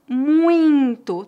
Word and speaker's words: muito: Agora eu gostaria de falar sobre mais um pequeno detalhe, a pronúncia da palavra muito. É uma pronúncia nasal.